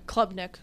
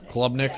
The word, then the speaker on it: Klubnik: And it's K-U-B-N-I-K, right? That's how you spell it? – Klubnik. – Klubnik.